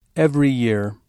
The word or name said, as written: every year